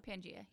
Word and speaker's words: Pangaea: I think it's Pangaea. – Pangaea, yeah.